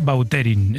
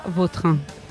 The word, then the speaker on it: Vautrin: Es que a mí me suena mejor Bauterín. Es como... – Da más fuerza, ¿no? – Más fuerza, sí, más pronunciación. – Bauterín. Elegante. – La original francesa, Vautrin.